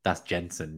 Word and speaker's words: Jensen: I thought that might have been like the Brentford midfielder, but no, that's Jensen, not Zensen.